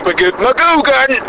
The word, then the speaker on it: McGugan: In fact, I would like McGugan! You have to say his name like that, by the way.